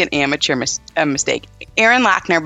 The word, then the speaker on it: Loechner: You know, it's Erin Loechner.